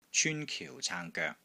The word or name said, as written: CYUN1 KIU4 CAANG1 GOEK3